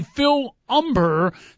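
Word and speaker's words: Phil Humber: He'll be going up against a White Sox rookie, Phil Umber. The H is silent, Big Dog. You know how I hate that silent first letter, but Phil Umber actually pitched pretty decent first game out, so an apparent mismatch, but you never know.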